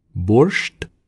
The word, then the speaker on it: borscht: Borscht.